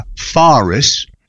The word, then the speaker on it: Faris: At number five, we've got Spa Weekend, a new film, a boisterous American comedy with Leslie Mann, Isla Fisher, and Anna Faris. That's how she pronounces her surname, not Faris.